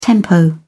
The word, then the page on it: tempo: British English